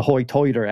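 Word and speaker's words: hoi toider: Hoytoider accent, which means high tide or high tide. But they, instead of saying I, like we would say high tide, they say Hoytoid.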